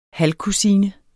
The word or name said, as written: halvkusine